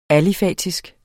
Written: alifatisk

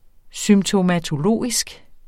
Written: symptomatologisk

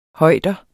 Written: højder

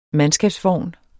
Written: mandskabsvogn

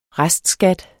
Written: restskat

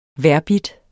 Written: vejrbidt

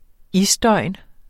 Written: isdøgn

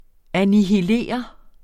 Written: annihilere

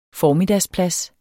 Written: formiddagsplads

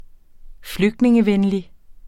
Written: flygtningevenlig

